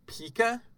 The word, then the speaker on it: pica: The next malady we have is something called pica. – Okay. – And I think that's how I... How do you spell it? – P-I-C-A.